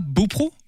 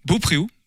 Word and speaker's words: Beaupréau: Gauvin qui était passé, alors là aussi c'est une question de prononciation, à Beaupro, Beaupréau, au choix.